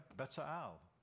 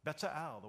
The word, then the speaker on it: Bezalel: And he says, no, that's right, Bezalel. Bezalel, the way he said it was really nice.